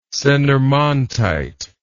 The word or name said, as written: senarmontite